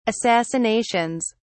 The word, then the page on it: assassinations: British English